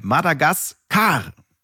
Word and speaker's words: Madagaskar: Betonung auf der letzten Silbe. Madagaskar.